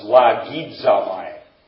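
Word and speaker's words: lagidzamai (λογίζομαι): It's pronounced lagidzamai. Lagidzamai.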